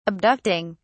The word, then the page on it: abducting: British English